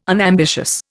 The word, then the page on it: unambitious: Indian